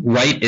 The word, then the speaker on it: Wright: My Twitter, Francis Wright PW. Wright is spelled WRIGHT.